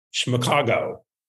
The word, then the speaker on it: Schmigadoon: Obviously, we have a lot to talk about, but let's just start off with, and I've been practicing how to say it, Schmigago. – Yeah. – Did I say it right? – Yes, you did.